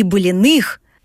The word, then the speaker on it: Блиных: Ирин, так что же обязательно нужно увидеть из наследия семьи Блиных, правильно поставила ударение, как местный житель, в вашем чудесном городе?